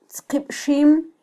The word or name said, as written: tzqibxim